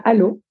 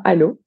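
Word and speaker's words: Allauch: à Allo, une petite commune limitrophe qui s'écrit Alloche, mais qui prononce bien Allo, avec mon papa, ma maman, dans une petite maison de lotissement.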